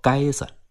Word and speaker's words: 街子: 街子在当地方言中读作该子，保留着一条条明清时期的街道和店铺，总有一两百年的历史。